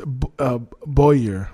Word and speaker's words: Bowyer: Oh, it's Bowyer. Okay, so that's how it's pronounced.